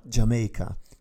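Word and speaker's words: D'yer Mak'er: scritta Dear Maker, ma che si pronuncia Jamaica, come Giamaica appunto, e che proviene da Houses of the Holy.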